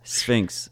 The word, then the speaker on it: Sphinx: I think you just said Fink's. – Sphinx. – Sphinx. – Sphinx? Sphinx. It's Sphinx. It's like the cat, the Egyptian cat statue.